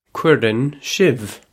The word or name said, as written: cuireann sibh